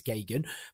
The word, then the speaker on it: Geoghegan: It's Gagan. But it sounds easy to spell when it isn't. It's G-E-O-G-H-E-G-A-N.